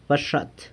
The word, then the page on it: baxat: San Lucas Quiaviní